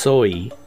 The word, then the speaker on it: Samhain: So this is what it says in Celtic traditions, or Halloween, which was called Soi, and it's pronounced Soi, but it's spelled S-A-M-H-A-I-N, right?